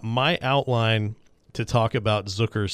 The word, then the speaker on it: Zucker: You had goals from Jason Zucker. It rhymes with Hooker.